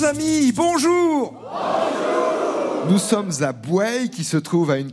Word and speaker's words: Bouaye: Bouaye, car on dit bien ainsi, on prononce bien ainsi le nom de cette commune, le nom qui s'écrit B-O-U-A-Y-E, mais on dit Bouaye en général, une ville résidentielle de la métropole nantaise, ville de viticulture.